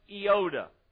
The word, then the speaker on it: iota: It is pronounced iota, I-O-T-A, iota.